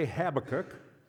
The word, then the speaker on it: Habakkuk: When I was a kid, I heard the first time I heard the word was when a preacher was preaching from Habakkuk, and he called it that, Habakkuk. That stuck. I might switch back and forth to some other pronunciation, pay no attention to that. Others say Habakkuk, or Habakkuk, or you say tomato, and I say tomato, and we're all wrong because we aren't pronouncing it the way the Hebrews did.